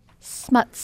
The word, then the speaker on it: Smuts: Smuts. – Smuts. So not Smuts, but Smuts.